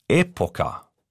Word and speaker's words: Epoca: Falsch gedacht, Dickelmann, das spricht man ja gar nicht so aus. Epoca wird italienisch betont. Auf der ersten Silbe, habe ich mir sagen lassen.